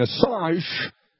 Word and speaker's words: Assange: The connection is coming from Julian Assange, that's how you say his name, who's the WikiLeaks guy.